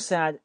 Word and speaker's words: said: You said she clocked out at 9. 我们先来看这个发音部分，and 这个 d 不发音，所以就听成了 and 那个 d 没有。 You said 这个 said 它的发音叫 said，中间是一个短元音 i 的发音。